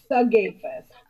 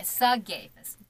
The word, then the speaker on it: Sigafus: So the S is completely silence on that. – No, it's Sigafus. – Okay, Sigafus.